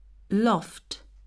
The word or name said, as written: loft